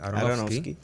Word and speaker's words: Aronofsky: Nos presentan a este personaje, que por cierto, la película, dirigida por Darren Aronofsky, perdóname, no sé si lo pronuncio bien, Aronofsky.